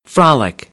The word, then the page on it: frolic: American English; ɑː - ɒ